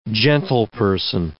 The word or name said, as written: gentleperson